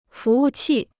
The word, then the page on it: 服务器: fú wù qì